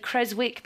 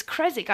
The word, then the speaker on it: Creswick: Pippa Tandy from, I would have said Creswick because I'm terrible at pronouncing things, but it's Creswick. I just learnt from Fee.